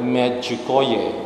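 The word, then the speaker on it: Međugorje: 咁我喺加拿大教書咧，就有個 partner 係烏克蘭人，烏克蘭嘅女士，咁佢又同我講呢個字咧係唔係英文啦？呢、這個就斯拉夫民族嘅語言，正確嘅讀音咧應該係麥主哥爺，咁咧即係同嗰個麥主哥爺嗰個拼音咧就好相似嘅，麥主哥爺嗰個先係正確嘅讀音。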